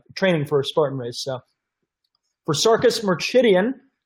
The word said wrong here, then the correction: Merdichian: I said that wrong. Merdichian.